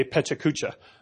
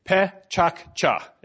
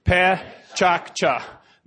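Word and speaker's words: PechaKucha: Mark Ditham, an Englishman living in Tokyo now for 25 years, he's customized this word to his own liking, and this is how you say it. Pe-chak-cha. Repeat after me. Pe-chak-cha.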